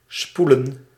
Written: wind

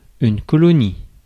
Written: colonie

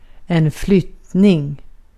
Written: removal

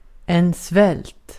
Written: svält

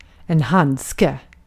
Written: handske

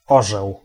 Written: aigle

